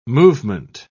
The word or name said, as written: movement